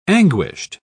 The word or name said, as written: anguished